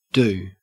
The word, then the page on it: do: Australian English